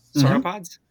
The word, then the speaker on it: sauropods: So then what are like sauropods? Did I say that right? Sauropods? – Mm-hmm.